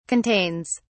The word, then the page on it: contains: British English